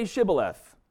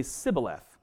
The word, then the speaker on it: Shibboleth: And then he would say to him, then say Shibboleth. And because he couldn't say it right, he'd say Sibboleth. And we don't actually have a great translation of what this word is. It might mean a branch, possibly, but that's about like the closest thing we get to it. But it is a pronunciation trick here. So Shibboleth, Sibboleth.